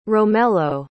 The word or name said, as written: romello